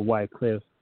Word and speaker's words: Wycliffe: his last name is spelled W-Y-C-L-I-F-F, or Wycliffe. He is considered by many historians to be a predecessor to the Great Reformation Movement. But the Great Reformation Movement is very important too. But John Wycliffe, along with his team, during the same time, during the 13th century, guess what they began to do?